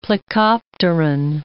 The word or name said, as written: plecopteran